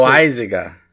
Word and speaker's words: Loaisiga: say Loaiziga? That's how it's said.